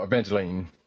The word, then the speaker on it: Evangeline: Oh, Evangeline.